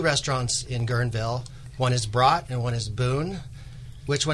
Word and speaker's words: Brot: Brot. So Brot. I always like to tell everybody, Steve, I'm going to get you a shirt. It says You can have a brot at Brot. It's a long O.